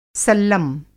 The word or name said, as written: सल्लम